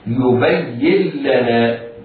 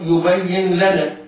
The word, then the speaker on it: يبين لنا: يبين لنا بعض المبتدئين يقرأ بالإظهار يبين لنا هذا خطأ.